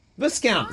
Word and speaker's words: Viscount: Viscount. – It's got an S in it. Viscount. It's like discount. Put it with a V. – It's like the S in disco. You don't pronounce it.